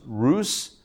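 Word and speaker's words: Rus (רות): Her Hebrew name was Rus or Ruth, but Rus, the way we're pronouncing it, right?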